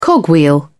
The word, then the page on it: cogwheel: British English